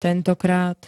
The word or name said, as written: tentokrat